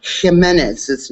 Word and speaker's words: Jimenez: Well, I love how you properly pronounce the J with the H sound, but you can't do it with Jennifer Jimenez's name.